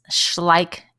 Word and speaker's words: Schleich: So this week's product that we're loving is a toy that Rosie and Adam talk about, and it's Schleich Toys. That's spelled S-C-H-L-E-I-C-H.